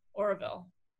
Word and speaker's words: Auroville: A-U-R-O-V-I-L-L-E, Orville.